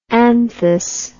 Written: anthous

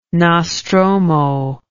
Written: nostromo

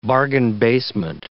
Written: bargain-basement

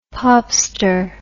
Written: popster

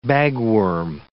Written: bagworm

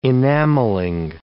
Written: enamelling

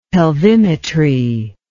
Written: pelvimetry

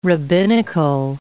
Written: rabbinical

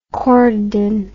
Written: corydon

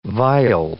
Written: viol

